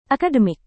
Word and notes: akademik: indonesian

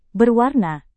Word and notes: berwarna: indonesian

